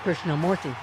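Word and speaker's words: Krishnamoorthi: Thank you, Representative Krishna Murthy. I did say that right. – You did say it right.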